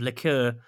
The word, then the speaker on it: liqueur: liqueur. How do you say that word? Liqueur, yeah, that's it.